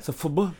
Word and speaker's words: FUBU: Some FUBU.